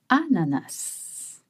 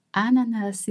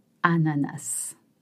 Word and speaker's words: ananas: Ananas is interesting because in French, the French, they don't pronounce the consonants at the end of French words. It means that some people will say ananas and some others might say anana. Now, the most common pronunciation is ananas.